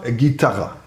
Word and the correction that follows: Gitana: Naja, Gitana, aber ist nicht so schlimm.